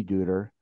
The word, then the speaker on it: Duder: One of the low points was the passing of his mate, his muse, the love of his life, his wonderful wife, Dorothy Dudler. Am I pronouncing the last name correct? – Deuter. It's like the Big Lebowski.